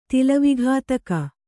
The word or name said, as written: ತಿಲವಿಘಾತಕ